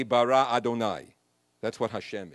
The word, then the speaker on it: Adonai: The God that whose name we pronounce is Adonai. It's spelled Yud-Heh-Vav-Heh.